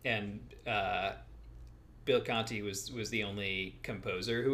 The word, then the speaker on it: Conti: So we get our first real taste of Bill Conti. Is that how you say his name? – Yeah, Conti. I think Conti.